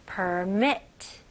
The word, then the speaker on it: permit: 네, 발음은요. per, 그 r 발음 잘 해주셔야 됩니다. per, 혀 당기는 느낌. permit. permit. okay? permit. okay, 강세가 어디에 들어갈까요? 네, 두 번째 음절이에요.